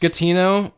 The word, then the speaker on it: Schettino: It's pronounced Schettino, but I on the documentary I watched, the guy kept calling him Schettino because and that may characterize him.